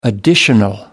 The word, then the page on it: additional: American English